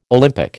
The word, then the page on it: Olympic: American English